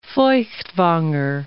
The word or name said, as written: Feuchtwanger